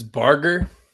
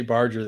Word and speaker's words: Barger: Justin, it's Barger. It's a hard G.